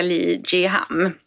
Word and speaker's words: Lee Jiham: Jag är blown away och den här lilla hörluren, det är ju alltså Ion Enhancer från koreanska Lee Jiham. – Ja, bra. Nu uttalade du rätt.